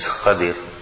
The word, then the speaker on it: Khadir: We were discussing the incident of Hazrat Musa alayhi salatu wasalam and what we call Hazrat Khidr, and the ulama have written the correct pronunciation is Khadir.